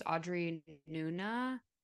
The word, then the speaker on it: Audrey Nuna: One is Audra, I think her name is Audrey Nuna, is how you pronounce it,